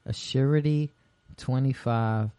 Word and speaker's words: Asurity25: Assurity25.